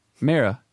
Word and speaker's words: Mera: Is it Mara? – Mara. Yeah. – Okay. Good to know. – M-E-R-A.